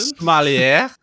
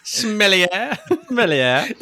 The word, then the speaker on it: sommelier: And how's it pronounced again? – Sommelier. – Sommelier.